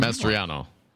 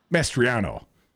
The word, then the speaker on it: Mastriano: Did I say that right? – Mastriano. – Mastriano. Tell me, Sicilian, how do you say it? Mastriano.